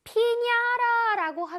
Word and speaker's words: piñata: 아까 그 paper toy를 피냐라라고 한다고 합니다. 근데 이 원래 잘 읽어보면 피나타인데요. 이 나라 멕시코의 발음으로 하면 피냐라라고 읽는다고 합니다.